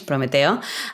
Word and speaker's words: Prometeo: No, you're doing a fantastic job pronouncing that, which is quite Spanish, Prometeo.